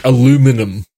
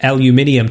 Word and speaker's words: aluminium: Well, that one's an interesting one because aluminum was discovered in America and named aluminum, and then the British scientific community renamed it aluminium to rhyme with silicon, beryllium, and all of the other existing elements.